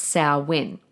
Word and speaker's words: Samhain: It looks like Samhain, Samhain, but it's pronounced Samhain.